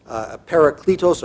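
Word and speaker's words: parakletos (παράκλητος): I'm going to guess many of you have heard that word before, parakletos, but I'll spell it. It's P-A-R-A-K-L-E-T-O-S, parakletos, sometimes also paraclete.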